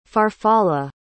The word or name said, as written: farfalla